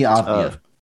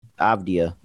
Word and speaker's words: Avdija: How you say his name? – Denny Avdija. – Avdija, yes. Yeah, Denny Avdija for the Wizards.